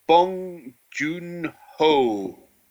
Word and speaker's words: Bong Joon-ho: by Parasite director, I'm gonna fucking butcher his fucking name again, Bong Joon-ho.